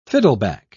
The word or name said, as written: fiddleback